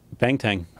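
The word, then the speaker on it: banteng: How do you say it? – Bangtan. – Spell it, excuse me. – Bangtang. – Bangtan. – Yeah, I believe. You better bring it up. – It's one word? – One word, yeah, Bangtan.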